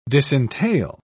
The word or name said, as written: disentail